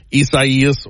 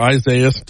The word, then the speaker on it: Isaias: WBRZ's chief meteorologist, Dr. Josh Heath, just on with us for just a couple more minutes as we're talking about tropical storm Isaias or Isaias, depending on how you choose to pronounce it.